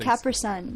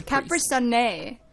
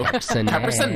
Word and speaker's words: Capri Sun: Do you pronounce no, Capri Sun? Because I knew someone who was from New Zealand who would say it's Capri Sunne.